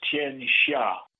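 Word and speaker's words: Tian Xia (天下): Yeah, it's it's T I A N, and the next word is X I A, and it's pronounced Tian Xia and the Shah is not quite the same as the English SH. It's not like the she, although Xi Jinping is a little like the she,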